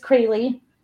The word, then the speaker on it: Crayle: Well, my name is Crayley, and so if you see it spelled down there and pronounce it the way it looks like it should be pronounced based on how it's spelled, my feelings are not hurt, like ever.